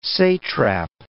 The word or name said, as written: satrap